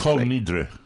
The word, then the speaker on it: Kol Nidre: Kol Nidre.